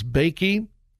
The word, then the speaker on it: Bakke: the Regents of the University of California versus Bakke, I believe that's how you pronounce it, B-A-K-K-E.